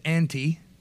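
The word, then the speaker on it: anti (ἀντί): And the Greek word used in the place of for was that thing on the far right, which is pronounced anti.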